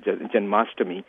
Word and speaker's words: Janmashtami: the Krishna Janmashtami, the way we would pronounce it, Janmashtami, is basically a remembrance, so to speak.